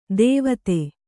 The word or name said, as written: ಧಾವತಿ